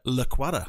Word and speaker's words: Lacuata: And our special guest tonight is Francesca Laquada.